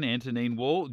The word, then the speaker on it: Antonine Wall: And the Romans got as far into Scotland as the Antonine Wall? Is that how you say it? Yeah. – Well, yeah, because they built that wall. – Antonine Wall, just outside Falkirk.